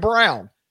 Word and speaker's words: Braun: B-R-A-U-N. Oh, so Christian Braun. No, Christian Brown. There is no scenario that doesn't spell Brown.